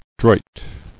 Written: Droit

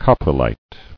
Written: Coprolitic

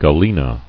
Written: Galena

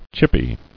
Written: Chippy

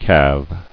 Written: Calving

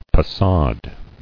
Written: Passade

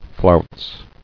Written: Flounced

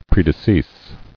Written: Predecease

